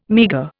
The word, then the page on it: meagre: Indian